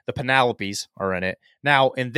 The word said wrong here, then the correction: panoply: Panoply, whatever.